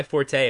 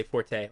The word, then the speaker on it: forte: Another one of those is when people say that's not my forte. Apparently you're not supposed to say forte. Like forte is just the musical term. You're supposed to say that's not my fort, and it's still spelled with an E.